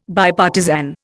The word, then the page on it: Bipartisan: Indian